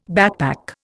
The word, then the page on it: backpack: Indian